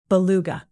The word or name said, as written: beluga